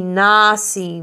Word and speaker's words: nasi: But we want to put the emphasis on the na. Ili nasi mwimbe.